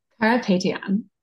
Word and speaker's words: Carapetyan: Carapetyan.